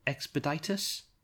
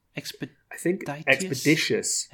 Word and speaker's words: Expeditious: Yeah, can you help me with the pronunciation here? Is it Expeditus? – Expeditious. – Expeditious? – Expeditious, yeah.